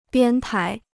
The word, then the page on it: 编排: biān pái